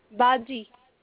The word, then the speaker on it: Baji: How you pronounce that? – Baji. – Baji. I knew I would say it wrong.